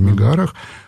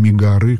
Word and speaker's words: Мегары: И когда в Пелопоннесском союзе, а Мегары было, Мегары по-гречески Мегара, но мы произносим Мегары как бы во множественном числе,